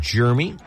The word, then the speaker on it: Germie: It is Jeremy, even though it starts with a G. – On this show, he's known as Jeremy. It's spelled G-E-R-M-I-E. Jeremy. There's no I between the R and the M for Jeremy.